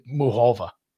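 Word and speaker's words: Muchová: But I heard someone who actually I think knows who she is say Muchova.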